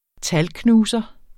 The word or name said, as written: talknuser